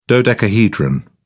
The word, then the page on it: dodecahedron: British English